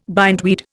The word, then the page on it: bindweed: Indian